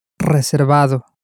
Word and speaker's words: reservado: Reservado. – Reservado. – Again, keep in mind that the V in Spanish is not pronounced exactly like a V in English, but somewhat more towards a B.